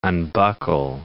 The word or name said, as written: unbuckle